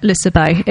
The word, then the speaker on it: Liseberg: It's in amongst all of that, of course, you do have their equivalent of Tivoli, which is Liseberg. How do you pronounce it? – Liseberg.